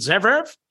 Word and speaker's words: Zverev: And he likes a guy named, well, I can't pronounce it, Zverev? Yeah, Z-V-E-R-E-V at +145 today at the ATP Monte Carlo.